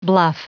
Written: bluff

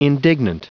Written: indignant

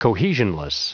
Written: cohesionless

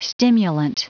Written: stimulant

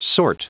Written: sort

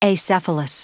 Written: acephalous

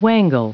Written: wangle

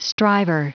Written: striver

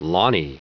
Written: lawny

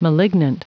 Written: malignant

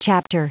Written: chapter

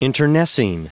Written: internecine

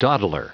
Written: dawdler

